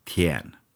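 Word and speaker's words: tien (田): Så det var lätt att byta ut tecknet för himmel till tecknet för fält, som också uttalas tien.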